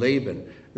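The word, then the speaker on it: Laban (לבן): Laban, this is how it's spelled, and I'm going to not use the final form, but it's Lamed Beit Nun, Laban.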